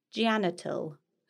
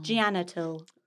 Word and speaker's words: Jannatul: I met a lovely lady called Janital. – Oh. – J-A-N-N-A-T-U-L. – Oh. – Janital.